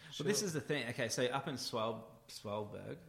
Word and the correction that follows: Svalbard: am I saying that right? – No. So it means cold shores. Svalbard.